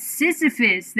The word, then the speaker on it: Sisyphus: How do you say that? Sisyphus, there we go, there it is.